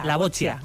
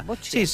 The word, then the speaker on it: boccia: Además que son muchas, pero nos vamos a centrar especialmente en la bochía. Cuéntame, en la bochía, así se pronuncia. – Bochia. – Sí, se escribe B-O-C-C-I-A, pero bueno, hay que italianizarlo un poquito.